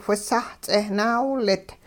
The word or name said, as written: whisah-ts'eh naawilit